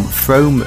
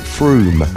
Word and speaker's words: Frome: I would like to nominate an unknown person from Frome, Somerset as a twat. – Now, I believe you pronounce it Froome.